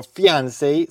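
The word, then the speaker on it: fiancée: Fiancé o fiancé, se pronuncian igual, para prometido o prometida.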